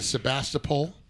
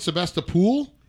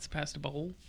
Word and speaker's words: Sebastopol: They call it Sebastopol? – Yeah. – Well, where's the other O? – Yeah. Where's the other O? That's the way it's pronounced. Not Sebastopol. Ain't no pole at the end.